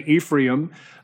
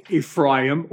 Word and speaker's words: Ephraim: That's E-P-H-R-A-I-M. And I said, I got fancy. And I said, well, actually, many people would say Ephraim, but a more correct pronunciation is Ephraim.